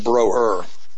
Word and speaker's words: Brower: Like my name with an umlaut over my name's actually supposed to have an umlaut. Brower. It's supposed to be brer is what it's supposed to be, and we pronounce it broer. – Okay. Well, anyway, I believe that this P P-O-C-H-U-M-A-W over the O, did go to Indonesia and die in Indonesia in 1970.